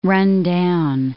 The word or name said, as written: run-down